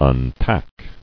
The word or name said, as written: Unpack